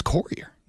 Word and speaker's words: courier: The word is pronounced courier. – No, it's not. – It is. There's a U in it.